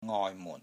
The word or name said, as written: NGOI6 MUN4